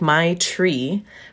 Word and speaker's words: maitri: So it sounds like my tree, but it's spelled M-A-I-T-R-I.